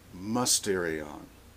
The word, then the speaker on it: mysterion: In print it looks like mysterion, M-Y-S-T, but that's pronounced mysterion.